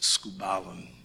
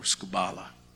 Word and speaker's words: skubalon: It comes from a Greek word, which is pronounced skubalon or skubala.